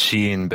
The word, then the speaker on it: Shein: They are, and it's pronounced Shein, Bill.